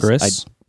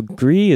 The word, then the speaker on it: Gris: The question I was going to ask is, I saw on the Nintendo eShop that there is a game called, I assume it's Gree, because Gris? Gers? Yeah. Girls? – So Gree is gray in French, and that's how you spell it.